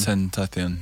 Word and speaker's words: XXXTentacion: XXXTentacion. – Tentacion, yeah.